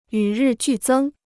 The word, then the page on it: 与日俱增: yǔ rì jù zēng